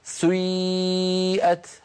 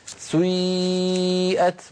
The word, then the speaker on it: سيئت: كلمة سيئة أصلها سيئت، سيئت على وزن فعل، لأن هذه الأفعال سيئة وأخواتها أفعال مبنية للمجهول، فبعض القبائل العربية كانوا ينطقونها بيبدأون بضمة ثم يعودون إلى الخلف فينطقون ياء هكذا سيئت، هذه الحركة يسميها العلماء حركة مركبة، مركبة يعني من حركتين أولها ضمة وآخرها كسرة كما نطقتها لك، سيئت وجوه الذين كفروا، هكذا تنطق يا أخي الكريم، بارك الله فيك.